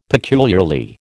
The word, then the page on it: peculiarly: American English